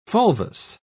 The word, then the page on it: fulvous: British English